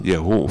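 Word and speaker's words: Jehú: Han anunciado la llegada del delantero más productivo nacional en el Clausura, William, se escribe J-E-H-U, Yehú Fajardo.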